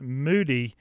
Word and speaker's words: Mudi: Well, the Russian Toy, the Moody is spelled M-U-D-I, but pronounced Moody, you know, was also a shepherding dog.